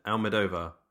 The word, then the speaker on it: Almodóvar: The Skin I Live In is a 2011 Spanish psychological thriller film written and directed by Pedro Almodóvar. – Is that how you're pronouncing it? Almodóvar? – Almodóvar.